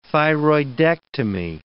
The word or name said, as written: thyroidectomy